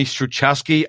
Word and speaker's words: Struczewski: So how you say my name, I tell people it's Struchess, like the game, and then key. But you actually said it right. If I can get people to call me Stucheski, I'm okay. But that's not technically how you say it.